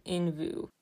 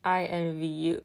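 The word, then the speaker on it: INVU: Raise your hand if you thought, at first, this album was pronounced Envu. It's okay, I did too. And then I felt really dumb. It is pronounced I Envy You, which I think is a really clever name, but it may not have been the name of the album at first.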